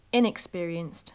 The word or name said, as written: inexperienced